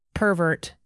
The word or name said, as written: pervert